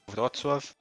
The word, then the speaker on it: Wrocław: Como que fala Wroclaw? Wrocław.